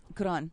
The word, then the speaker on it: Quran: Quran. Yes. – Quran. – Well, it's spelled Q-U-R-A-N.